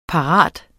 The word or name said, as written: parat